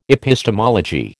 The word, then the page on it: epistemology: American English